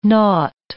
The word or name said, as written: naught